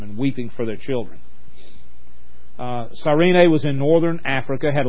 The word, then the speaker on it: Cyrene: As they led him away, they seized a man, Simon of Cyrene, sometimes pronounced Cyrene, who was coming from the country, and they laid the cross on him and made him carry it behind Jesus.